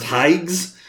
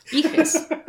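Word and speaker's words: Higes: It's not pronounced Higgs. – Iges. Higgs.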